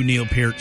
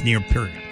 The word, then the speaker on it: Neil Peart: Fill in for Neil Peart. And that's how you say it. Eddie Trunk taught me how to say it. And Eddie knew Neil Peart. So if Eddie says it's Neil Peart, it's Neil Peart. Some people say Peart. I used to say Peart until Eddie corrected me.